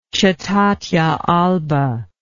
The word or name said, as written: cetatea alba